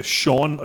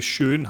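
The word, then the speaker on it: Sjón: Uh, jeg kan godt lide, jeg tror, at man udtaler det, uh, Sean og ikke Sjøen, ham her, uh, den islandske forfatter og poet, som har uh, været med til at skrive den her film.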